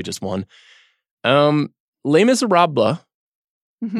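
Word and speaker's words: Les Misérables: Am I saying that correctly? You're the French master on this show. – Yeah, that was good. – Okay. How would you say it? – I'm not actually French. So Les Miserables, you don't have to do the blah, but...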